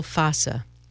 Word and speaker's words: fossa: Oh, popliteal fascia. – Fossa. – Is that how you say it? – F-A-S-C-I-A? F-O-S-S-A. – Fossa.